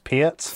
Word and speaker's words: Peart: It's actually pronounced Peart.